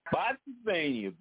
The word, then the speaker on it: Spotsylvania: And out of Spotsylvania, correct? Did I say it right? – Spotsylvania, beautiful Spotsylvania, okay, Spotsylvania, Virginia.